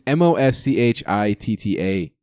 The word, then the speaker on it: Moschitta: His name is actually John, and I might butcher this. John Moschitta? – You probably butchered it. – Moschitta? Yeah. – It's Moschitta Jr. He was actually the voice for Micro Machines.